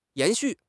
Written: 延续

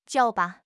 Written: jiao3

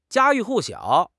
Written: 家喻户晓

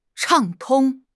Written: 畅通

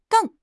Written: 瞪

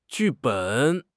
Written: 剧本

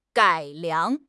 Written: 改良

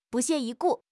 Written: 不屑一顾

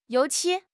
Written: 油漆

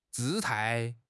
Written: 姿态

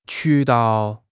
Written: 渠道

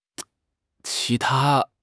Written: qi2 ta1

